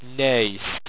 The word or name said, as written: Naest